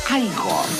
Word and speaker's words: Igor: No, se pronuncia Igor.